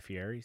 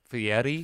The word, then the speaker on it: Fieri: I'll reach out to my buddy Guy Fieri, see if he wants to cater, maybe. – Sorry, it's pronounced Fieri.